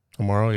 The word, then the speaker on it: Amaro: Am I saying that right? – Amaro, yeah.